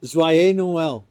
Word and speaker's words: Joyeux Noël: How do you say Merry Christmas? Joyeux Noël. – Joyeux Noël. Yes.